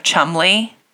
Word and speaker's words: Cholmondeley: He's the Marquess of Chumley. And do you know that name? – It was in the news, wasn't it? – It's spelled like Cholmondeley. – Yeah. – It's one of those very goofy British last names.